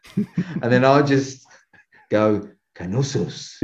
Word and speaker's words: Knossos: No, it's uh, Knossos. I think it's just pronounced Nosos, but it's Knossos in Crete in uh, in Greece.